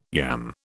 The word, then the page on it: yam: British English